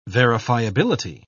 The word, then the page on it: verifiability: British English